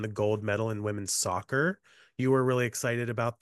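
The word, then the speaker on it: Sokkerstr: Soccer.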